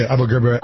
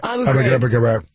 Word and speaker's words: Abu Ghraib: Was that whole part of the Abu Ghraib? How do you say that? Prison. Abu Ghraib. – I'm laughing because this is a Bush had trouble saying it. You say Abu Ghraib.